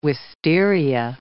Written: wisteria

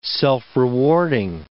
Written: self-rewarding

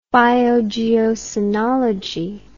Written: biogeocoenology

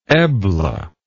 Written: Ebla

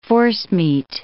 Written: forcemeat

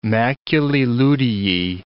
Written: maculae luteae

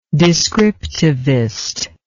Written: descriptivist

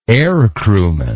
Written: aircrewman

